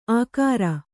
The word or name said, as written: ಆಕಾರ